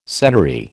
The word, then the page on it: senary: American English